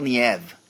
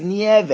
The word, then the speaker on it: Nieve: Why do you not pronounce that E at the end? It's not a difficult thing. It's not like, well, I can't roll my R's. No, it's Nieve. – It's one more syllable.